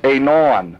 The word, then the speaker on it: anan: The actual word cloud is anan, A-N-A-N, or pronounced anon, A-W-N-A-W-N, and it's a bright cloud.